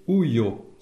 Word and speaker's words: ujo: Un premier, uyo. Ça s'écrit U-J-O. Et ça veut dire contenant.